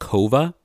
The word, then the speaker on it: Coava: The coffee roaster that we are tasting today is called Kova. It's spelled C-O-A-V-A, but it's pronounced Kova.